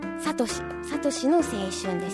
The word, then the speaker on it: 聖の青春: 先週のですね、放送で年始におすすめの映画としてサトシの青春なんですけど、ですね、それを私はずっと聖の青春とこう読んでて、それをですね、魚水さんに指摘されて、本当にありがとうございます。聖なる夜の聖と書いてサトシの青春ですね。